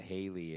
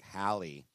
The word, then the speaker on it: Hallie: It's not Haley. It's like Hallie.